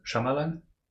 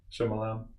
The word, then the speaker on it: Shyamalan: It just occurs to me, you don't pronounce the Y in his name, right? It's just Shyamalan? Wasn't there something with movies with Mikey where an actual Indian person pronounced his name and it's just pronounced Shyamalan? – Probably.